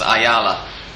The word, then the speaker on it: Ayala: In case any of our Anglophone listeners aren't quite entirely sure who we're talking about, it's Roberto, you're probably known as Ayala, but Argentines pronounce the Y as a sh.